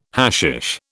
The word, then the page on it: hashish: British English